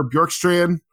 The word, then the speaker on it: Bjorkstrand: Oliver Bjorkstrand, got to make sure I'm pronouncing that name right.